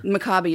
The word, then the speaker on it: Maccabi: Maccabi is just the proper way to pronounce Maccabi.